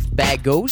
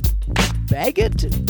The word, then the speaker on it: Bagot: For those who don't know, it's taking place at the Oshawa Public Library at 65 Bago Street in Oshawa. Maybe it's Bagot, but it's B-A-G-O-T. How would you pronounce it? Bago. I think it's Bago.